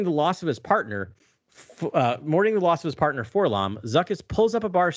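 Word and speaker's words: 4-LOM: So it's actually for LOM and Zuckus, because every time you see his name in this issue, it is phonetically spelled out for LOM.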